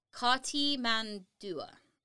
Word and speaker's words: Cartimandua: So we are going to talk about Cartimandua. I'm pretty sure that's actually how to pronounce her name.